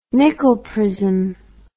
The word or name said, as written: Nicol prism